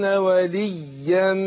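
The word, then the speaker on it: وليّا: خد بالك كلمة وليا دي مشددة. لو نشاهد اخواننا المشاهدين الياء في وليا مشددة انطقها ازاي؟ هقول ايه؟ ومن يتخذ الشيطان وليا وليا من دون الله فقد خسر خسرانا مبينا كده.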